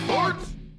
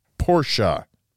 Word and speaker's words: Porsche: It's about cars, it's not about ports. – It's pronounced Porsche.